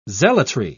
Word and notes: zealotry: British English

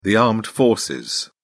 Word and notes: armed forces: British English

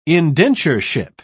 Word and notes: indentureship: British English

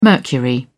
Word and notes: mercury: British English

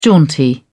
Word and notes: jaunty: British English

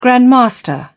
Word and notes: grandmaster: British English

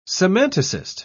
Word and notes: semanticist: British English

